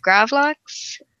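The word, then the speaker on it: gravlax: Basically a nice rye bread and gravid lox, usually pronounced gravlox.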